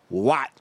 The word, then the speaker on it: Watt: Kelly Watt, like JJ Watt. – Gotcha. – Spelled the same way.